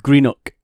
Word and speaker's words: Greenock: So I was born in a place in the west of Scotland called Greenock. So it's spelled like G-R-E-E-N-O-C-K, Greenock. Okay, Greenock. – And a lot of people who don't come from Scotland call it Greenock, right? Okay. But it's Greenock.